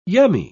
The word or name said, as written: yummy